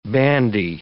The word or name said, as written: bandy